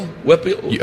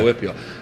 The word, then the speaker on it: WPIAL: Do you say Whipple? – Whipple? Whipple, like WPIA. – Yeah. – WPIAL. – Yeah, yeah. Whipple.